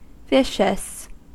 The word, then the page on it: vicious: American English